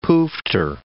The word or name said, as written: poofter